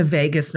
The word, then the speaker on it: vagus: So pressing into this a little bit, you gave a talk where you mentioned the vagus nerve.